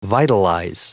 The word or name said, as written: vitalize